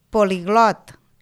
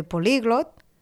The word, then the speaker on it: políglot: poliglot en lloc de políglot o radar en lloc de radar, que molts parlants, com us he pronunciat, ho fan com a plans.